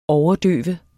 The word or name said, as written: overdøve